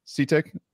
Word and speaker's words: CTIC: He is a former Navy chief, CTIC. Am I saying that right? CTIC? – CTIC. – CTIC. Okay. We'll just say all the letters, whatever.